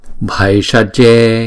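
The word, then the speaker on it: bhaiṣajye: Tatya tha tatya dia dia tatya tatya tha om om bhai sa je bhai sa je. Chữ bh là chúng ta đọc là bh bh. Chữ ai là một cái nguyên âm thì chúng ta đọc là ai ai bhai sa je bhai sa je.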